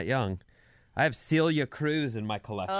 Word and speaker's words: Celia Cruz: Celia Cruz.